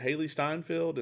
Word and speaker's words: Hailee Steinfeld: is it Hailee Steinfeld? Is that the pronunciation? – I think so. – Yeah, we can fact check that real quick. Yeah, so let's see. Yeah, so she is going to be played. Yep, I was correct.